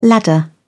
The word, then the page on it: ladder: British English